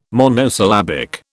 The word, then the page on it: monosyllabic: British English